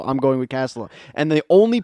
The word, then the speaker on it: Castilla: I told him, listen, you white man, it's Castilla. It's a Spanish word.